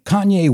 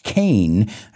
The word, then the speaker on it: Kanye: It's not Kane. I think a lot of people say Kane. It's Kanye. Kanye West.